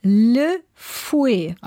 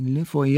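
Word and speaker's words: Lefuet: Ach so, und dann französisch ausgesprochen. – Ja. – Le Fouet. – Ah, okay, Le Fouet, ja. – Also mit T, was man aber nicht mitspricht. Tricky, oder? – Aber ich meine, Teufel.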